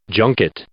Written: junket

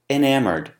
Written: enamored

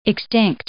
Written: extinct

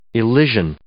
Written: Elysian

